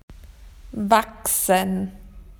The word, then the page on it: to grow: Australian English